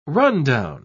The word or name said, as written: rundown